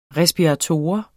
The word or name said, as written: RESPIRATOR